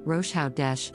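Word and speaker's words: Rosh Chodesh: This is a Hebrew word pronounced Rosh Hodesh, which literally means head of the month commonly known as the celebration of the new moon.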